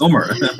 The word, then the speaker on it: Umar: It's Umar.